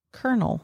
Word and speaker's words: colonel: Colonel is spelled, for those of you that may not know, mm-hmm. C-O-L-O-N-E-L. Colonel. – Colonel. – It's from a French word. – Okay. – That the Americans were like, no, we're going to still say colonel, but we're going to spell it different.